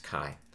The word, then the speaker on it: Mackay: And his last name is spelled like Key, but it's actually pronounced Kai.